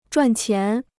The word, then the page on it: 赚钱: zhuàn qián